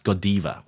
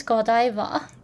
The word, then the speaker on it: Godiva: It's actually pronounced Godiva. Then why my professor told me it's Godiva? – Because they learned it from American advertising. So all the American advertising calls it Godiva.